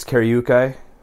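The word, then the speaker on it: Karyūkai: It's K-A-R-Y-U with the thing over it, K-A-I. – Yeah, I believe it's Karyukai is the correct pronunciation of it.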